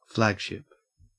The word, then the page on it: flagship: Australian English